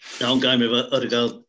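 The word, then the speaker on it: Ødegaard: Just on Odegaard, it looks as though he's off to Arsenal on a loan. We should be pronouncing it Odegaard, apparently.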